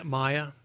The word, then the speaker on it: Maya: Yeah, Maya.